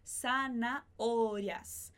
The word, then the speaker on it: zanahorias: E aí, zanahorias, a gente tem um H no meio da palavra, a gente não pronuncia o H, mas a gente vai pronunciar o A que vem antes e o O que vem depois. Então, zanahorias.